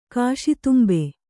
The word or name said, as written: ಕಾಶಿತುಂಬೆ